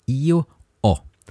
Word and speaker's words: io ho: So the first one is I have is io o. So you write this with H O, like ho, but you have you have a silent H, you don't say the H.